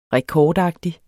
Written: rekordagtig

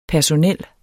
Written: personel

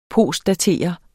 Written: postdatere

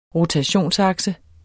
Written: rotationsakse

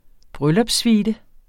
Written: bryllupssuite

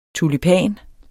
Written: tulipan

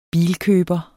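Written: bilkøberne

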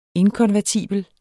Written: inkonvertibel

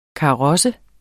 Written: KAROSSERNE

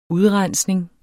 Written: udrensning

